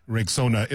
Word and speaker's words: Rexona: It is pronounced Rexona.